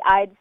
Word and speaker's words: Ihde: It's just my first and last name, except while my last name is spelled I-H-D-E, my studio is called Carly Eyed Studio, and Eyed is spelled E-Y-E-D, so that people know how to say it right.